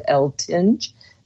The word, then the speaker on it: Eltinge: Well, my book is called The Dream Class, subtitled Know Your Dreams, Know Yourself, by me, Patricia L. Tinge. My last name is spelled E-L-T-I-N-G-E.